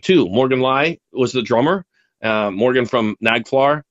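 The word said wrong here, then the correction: Naglfar: I think that's how you say the name. Nagflar. Always can never pronounce that name. – I can't pronounce it either, but yeah, something like that. – Nagelflar. – There you go.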